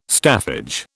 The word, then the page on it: staffage: British English